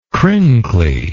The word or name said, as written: crinkly